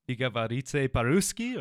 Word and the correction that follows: Я говорю по-русски: Я говорю по-русски, конечно.